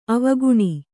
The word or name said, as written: ಆವಿಕಣ್ಣು